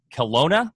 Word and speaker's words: Kelowna: Curtis's farm, Green City Acres, is located in, excuse me if I butcher this, Kelowna, BC, yep.